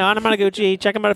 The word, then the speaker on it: Anamanaguchi: Anamanaguchi. – Anamanaguchi.